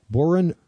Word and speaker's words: Bohren: I've heard him talk about Borin. And who? – I said, are those boring? – Those guys are boring. It's spelled B-O-H-R-E-N, but here's the full name. Boren und der Club of Gore.